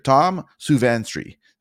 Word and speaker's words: Tom Suvansri: We have Mr. Tom Suvansri.